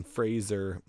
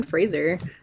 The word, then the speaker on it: Fraser: Shout out to Brendan Fraser. – Fraser. – I'm. Fraser. – Fraser. – Fraser. – No, you're saying it wrong. You're still saying it wrong. Zer. – Fraser. – Z-E-R.